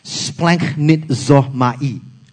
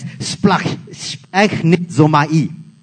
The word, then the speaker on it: splanchnizomai: So it's spelled as splanchnizomai. It's just like gibberish if you don't know, right? But it's pronounced as splachnizomai.